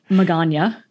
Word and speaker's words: Magana: And I always say her name wrong, but I have to remind myself that it's like lasagna with an M. Magana, Michelle Magana.